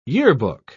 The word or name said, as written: yearbook